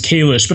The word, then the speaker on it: Kalish: No, we had this conversation before when we were doing the Celebrity Apprentice podcast, where it's actually pronounced Kalish. But I think at this point, Jordan Kalish has kind of become my RHAP alias almost.